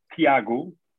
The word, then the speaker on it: Tiago: So my name in Portuguese is pronounced Tiago, and then my surname Teles,